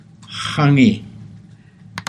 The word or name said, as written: xangii